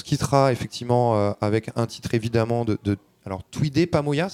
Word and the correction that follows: Twende Pamoja: c'est ça, euh, Vincent Oui, Twendy Pamoja. Twendy Pamoja, toujours mon problème de, de prononciation.